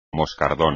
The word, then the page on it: moscardón: espagnol